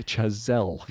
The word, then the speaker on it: Chazelle: It's pronounced Chazelle.